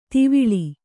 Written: ಧವಲಿ

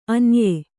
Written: ಆನಯ

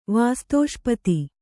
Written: ವಾಸ್ತೋಷ್ಪತಿ

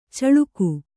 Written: ಚಿಲಕೆ

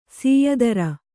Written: ಸಯ್ತಿರ್

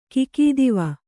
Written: ಖಗತ್ವ

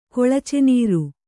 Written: ಕೊಳಚೆನೀರು

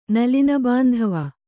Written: ನಲಿನಬಾಂಧವ

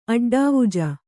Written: ಅಡ್ಡಾವುಜ

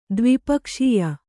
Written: ದ್ವಿಪಕ್ಷೀಯ

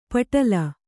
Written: ಪಟಲ

